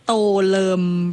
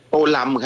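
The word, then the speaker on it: Tô Lâm: อ่านว่าโตเริ่มหรือโตลำคะโตลำครับผมอยู่ที่เวียดนามเขาเรียกท่านโตลำครับ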